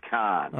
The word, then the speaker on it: Cannes: You can say Cannes, or you can say Cannes, but it's really somewhere in the middle there. It's sort of Cannes.